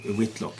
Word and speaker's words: Whitlock: Jag tror att det ska uttalas Whitlock. – Men... Whitlock, ja. Okej, okay, bra att du sa V-H-I-T-E.